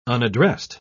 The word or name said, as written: unaddressed